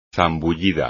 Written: zambullida

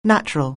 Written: natural

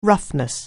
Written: roughness

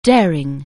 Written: daring

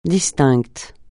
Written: distinct

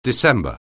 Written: December

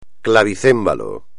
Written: clavicémbalo

